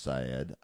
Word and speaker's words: Sayed: And please don't write me and say his name is pronounced Syed. I know his name is pronounced Syed. But it sounds goofier if you say Saeed.